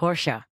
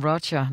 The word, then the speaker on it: Roger: Og navnet Roger på brasiliansk udtales ganske vist Rocha, men flere her i Europa kalder ham Roger nok for nemhedens skyld.